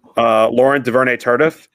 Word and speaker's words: Laurent Duvernay-Tardif: Laurent Duvernay-Tardif, I think I'm saying his name right.